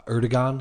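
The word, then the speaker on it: Erdoğan: I saw today that um, Turkey's uh, Erdogan.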